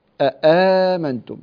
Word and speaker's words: أآمنتم: كلمة آمنتم أو أآمنتم على خلاف بين القراء، الذي يقول أآمنتم هذه كلمة فيها ثلاث همزات آ آ آ، فيصعب أن يجتمع، أن تجتمع همزتان، لكن إذا اجتمعت همزتان فإن بعض القبائل تنطقها. ماذا لو اجتمعت ثلاث همزات؟ لا يمكن، فتكون الثالثة عند كل القراء مبدلة، فهي أآمنتم.